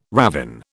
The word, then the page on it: ravin: British English